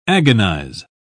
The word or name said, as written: agonize